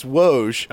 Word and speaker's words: Woj: He's classically known as Woj. – I'm going Waj.